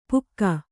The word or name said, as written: ಪುಕ್ಕ